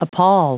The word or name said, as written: appal